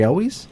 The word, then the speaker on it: Elwes: Carrie Ewells is in that movie. Do you remember that? – Ewells? – Twister? – Is that how you pronounce his name? – You're talking about Carrie Elwes? – Yeah.